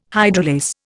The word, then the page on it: hydrolase: Indian